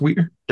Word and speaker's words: Weir: Is it pronounced Weir? W-E-I-R? – Yes.